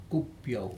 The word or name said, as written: kupiau